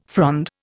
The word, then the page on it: frond: Indian